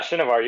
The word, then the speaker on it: Shinevar: Yeah, Shinevar, you got it.